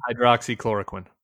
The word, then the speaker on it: hydroxychloroquine: Hydroxychloroquine.